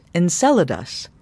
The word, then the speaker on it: Enceladus: Enceladus, pronounced Enceladus.